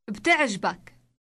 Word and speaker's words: بتعجبك: بتعجبك. بتعجبك. – Note the B and T sounds on the beginning.